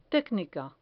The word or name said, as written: tecnica